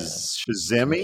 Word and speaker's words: Shazamme: Shazamme.